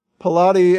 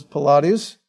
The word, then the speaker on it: Pilate: The family name, Pilatus, right, we'll pronounce Pilate, not Pilate as in the exercises Pilates, but Pilate.